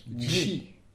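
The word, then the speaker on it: ĝi: Et pour le neutre, on dit ji, ça s'écrit G chapeau I.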